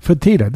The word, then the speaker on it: Fertitta: Dana White, of course, is the man who runs the UFC and who brought the UFC really, with the Fiedo brothers. I always mispronounce their name. It's okay. They're in line with everyone. Fertitta. There you go.